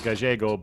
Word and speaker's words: Gallego: I'm sure it's pronounced Gallego, but because it was Sacramento in the late 70s, people anglicized.